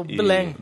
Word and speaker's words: Belém: É o Belém.